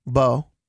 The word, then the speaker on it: Beaux: It's a Southern food trailer, but it's spelled B-E-A-U-X. – Bo. – Bo. Uh-huh. Okay, I've just never said that. I don't want to butcher. Here, okay. Look, this is it written down. Is that Bo? – Bo Southern Seafood. – Yep. – Yeah, I've never heard of it. – Oh, bro, it's so good, apparently. Everyone always talks about it. – Really? – And I never recommend it to anyone because I didn't want to, like, butcher the first. So it's Bo.